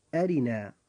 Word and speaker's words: أرنا: فمثلا كلمة أرنا، كلمة أرنا جاء حرف الراء فيها مكسورا فترقق هذه الراء عند النطق.